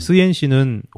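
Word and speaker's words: 쓰옌 (十堰): 얘네 발음은 쓰옌이라고, 쓰옌. 쓰옌시는 호북선에도 굉장히 유명한 큰 도시입니다.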